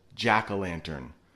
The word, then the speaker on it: jack o' lantern: And I know if you look up there, it has an O in it, but we really pronounce it jack o' lantern, more like an A instead of an O.